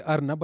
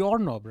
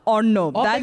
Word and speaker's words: Ornob: I mean, I would say Arnab, but it's actually Ornob, right? – Or Bengalis. That's so hard. Ornob.